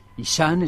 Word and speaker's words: Ishan: Ishan es un cantante de pop. Se escribe I-S-H-A-N,